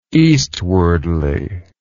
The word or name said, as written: eastwardly